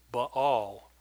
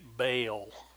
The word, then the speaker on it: Baal: And so he was explaining that the proper pronunciation for this is Baal. He said, not like all of you people who say Baal.